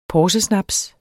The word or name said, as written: porsesnaps